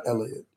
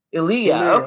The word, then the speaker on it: Elia: It's Elia. Cause how do you say his you name? Elliot. – Actually, say, actually, you say Elia. – Eliya, Okay, Eliya Kazan. You hit the E.